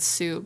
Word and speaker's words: Sioux: And they're not sure if it's named Tetons because of the big boob thing or if it's because of the Teton Sauk Indians. Like history. – Did you say Sauk? – I don't know how to say it. It's S-I-O-U-X. – It's the Sioux. The Sioux Indian.